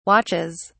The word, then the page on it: watches: British English